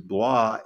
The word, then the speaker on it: Blois: Blois,